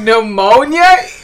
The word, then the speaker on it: pneumonia: Wait, no, y'all both said it wrong. Pneumonia? – Yes, pneumonia.